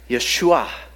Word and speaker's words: Yeshua: That word that we pronounce Jesus in the Greek was Iesous, and in the Hebrew was the word Yeshua.